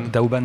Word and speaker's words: Douban: Douban. Daoban, Daoban, ça se prononce aussi.